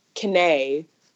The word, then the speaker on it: Kane: Like, his name is Kenneth, well, Kenny, and my name is Kane. So it's like Kenny with the long A, so that's how my name got about, because he named me and my mother named Cody.